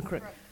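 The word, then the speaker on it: Kreuk: Kristen Crook. – Kristen Crook.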